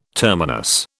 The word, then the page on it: terminus: British English